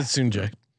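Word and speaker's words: Sungjae: This is the Soon JM redemption story we've been waiting to tell. Am I right? – No. – come on. – So it's Sunjay. – I pronounce it Sunjay.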